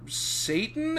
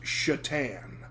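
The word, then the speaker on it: Šatan: And he says, "Actually, it's pronounced Shatan." And she says, "Oh, like the hockey player." For those that don't understand that reference, Miroslav Shatan, spelled S-A-T-A-N, but with a tick mark over the S, I believe, played hockey for a long time, played for, I think, probably best known for his time with the Buffalo Sabres.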